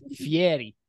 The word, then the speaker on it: Fieri: It's pronounced Fieri.